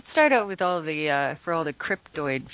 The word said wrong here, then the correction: cryptid: fans. Is that how you say it, Doug? – Cryptid? Cryptozoology, yeah. Cryptid yeah.